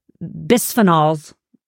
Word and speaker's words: bisphenols: Bisphenols.